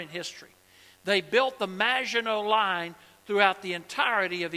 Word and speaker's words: Maginot: Maginot. That's how you pronounce it. It's French.